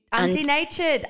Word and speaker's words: undenatured: It's important to mention that although collagen presentation doses used by the included studies were different, both collagen hydrolysate and unindentured collagen, I might need undentured? Could you undenatured? Undenatured? – Yes.